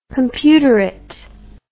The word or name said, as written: computerate